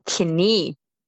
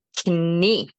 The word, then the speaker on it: knie: So contrary to English, we do pronounce the K when it is in front of an N. So it's pretty much written the same, except we do pronounce the K. Knie. Knie. Onder jouw onderbeen heb jij een voet.